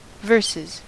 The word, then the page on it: verses: American English